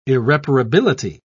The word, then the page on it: irreparability: British English